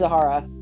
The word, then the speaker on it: Zahara: Okay, so it's Madox, Pax, Zarya. I'm sorry, I'm butchering that. – I think it's Zahara. – Zahara, yeah, that's it.